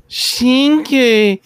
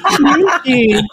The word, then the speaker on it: Shinkei: I just listened to the pronunciation and it says Shinkei. – Oh no. – It's Shinkei. It is just Shinkei.